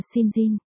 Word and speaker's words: St. John: Còn gọi là Blue, Harvard và Cowboy, cô Veronica St. John, đánh vần là Sen và John, hai từ nhưng phát âm là Sinjin.